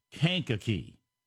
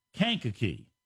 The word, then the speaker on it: Kankakee: It says, the name of the Illinois town is pronounced Kankakee. Thank you. I knew I was screwing it up somehow. Kankakee, Illinois.